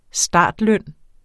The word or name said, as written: startløn